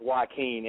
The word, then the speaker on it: Joaquin: Man, my name is pronounced Joaquin, and it's funny that happened, man. I get it all the time.